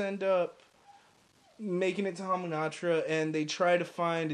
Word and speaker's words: Hamunaptra: Hamunaptra? – Hamunaptra, yeah.